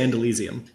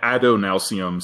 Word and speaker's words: Adonalsium: Is that how you pronounce it? I always say Andalusium. – Yeah, Adonalsium. So, because the root word is Adonai, like the Hebrew god.